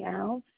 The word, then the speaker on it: Alves: Hi, you're speaking with Jamie Alves, A-L-V-E-S. – A-L-V-E-S.